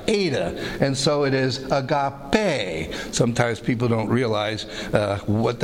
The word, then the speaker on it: agape: So we have love, and as you expect, it's the word agape, A-G-A-P-E. So we have A-G-A, agape, and this is pronounced as a long A.